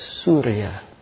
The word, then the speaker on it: Surya: Where I came from, it's pronounced as Surya, Cahaya, as in Indian Sanskrit.